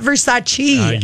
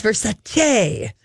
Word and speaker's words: Versace: She's like, it's not Versace. – Yes, she did. – Versace. We've been saying it wrong for years. Versace. It's Versace, not chi.